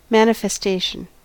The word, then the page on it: manifestation: American English